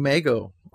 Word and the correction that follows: Migo: all my life, so okay, Migo, sorry.